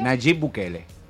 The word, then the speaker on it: Nayib Bukele: Nayib Bukele.